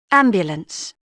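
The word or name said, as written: ambulance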